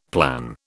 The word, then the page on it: plan: British English